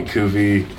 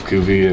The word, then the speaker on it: Cuvy: Well, my last name is Cuvelier, and everybody used to call me Cuvy and stuff growing up, Cuvy, and it rhymes with groovy, and I just feel like it kind of represents what I'm all about, so, you know, groovy, Cuvy, so.